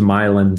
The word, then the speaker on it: Milan: This was on the way to a town pronounced Milan, spelled Milan.